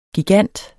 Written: gigant

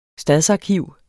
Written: stadsarkiv

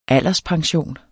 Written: alderspension

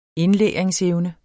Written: indlæringsevne

